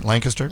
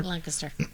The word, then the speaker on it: Lancaster: By the way, you love how I'm saying it correctly, Lancaster? – Lancaster.